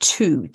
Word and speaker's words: two: The word two, T W O, used to be spelled T W A with the with the W pronounced. So my interpretation of that is as in toi. Over time, the A changed to an O and the pronunciation also changed.